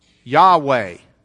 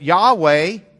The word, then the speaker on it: Yahweh: I don't want to get too bogged down, but Yahweh in the Hebrew, the Y is the same as a J. Yah? Yah. And the W is a German W. You're actually supposed to pronounce it as a V.